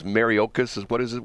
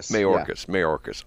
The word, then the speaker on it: Mayorkas: What is Mariokas? What's his name again? – Mayorkas.